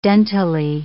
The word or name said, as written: dentally